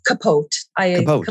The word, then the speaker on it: capote: Capote.